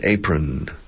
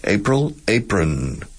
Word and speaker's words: apron: Delantal is an apron. Apron. Escrito apron. Y se pronuncia como el mes de abril. April, apron. Apron.